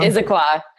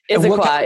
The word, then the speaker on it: Issaquah: Issaquah. Issaquah. It's a mouthful. – Issaquah.